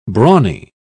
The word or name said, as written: brawny